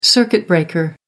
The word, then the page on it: circuit breaker: American English